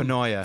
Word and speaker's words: Finoia: Fiona. It's Fenoya.